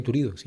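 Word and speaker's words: Turiddu: El Lola y Turido, sí, está bien. Sí, vale.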